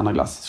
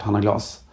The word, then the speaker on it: Hanna Glas: Jag skojar när jag säger Anna Glas, såklart. Hanna Glas. Eh, ja, du la eh, på man, det franska uttalet. Det var helt rätt.